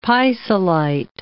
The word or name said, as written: pisolite